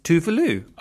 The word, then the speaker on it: Tove Lo: Well, at Gasmark 220, we have got the singer-songwriter Tove Lo. You might know her as Tove Lo. She's actually, the way you say her name is Tove Lo.